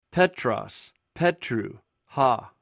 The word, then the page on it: petros: Greek